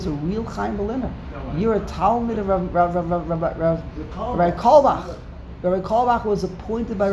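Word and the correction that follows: Carlebach: The name isn't Kalbach. – Rav Kalbach was the Rosh, was the Mashkiach. – The proper pronunciation of the name is Karlibach.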